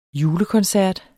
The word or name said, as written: julekoncert